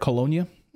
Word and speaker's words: Colonia: I got to ask you, when you look at these colonies or colonias, am I saying that right? Colonia. Colonia. Mm-hmm. Colonia.